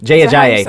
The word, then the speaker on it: Jay Ajayi: They had got them because AJ Ajayi was, oh my God, running like a madman. Jay Ajayi, my bad. – That's not how you say it.